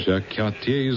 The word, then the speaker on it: Jacques Cartier: Jacques Cartier is the way it's pronounced. – But Jack Carter is pronounced differently.